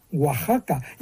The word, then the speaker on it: Oaxaca: Entonces, esa palabra así escrita se pronunciaba Oaxaca. Y hoy también, porque se ha mantenido, fíjate, la grafía española del siglo XVI.